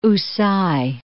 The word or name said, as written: Houssay